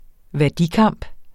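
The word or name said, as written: værdikamp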